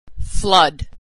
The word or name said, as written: flood